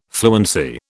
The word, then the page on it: fluency: British English